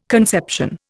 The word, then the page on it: conception: Indian